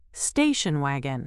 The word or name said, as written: station wagon